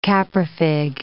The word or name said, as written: caprifig